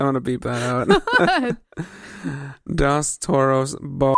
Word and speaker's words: Dos Toros: You pronounced it wrong. – Dos? – It's a burrito. Roll your R's.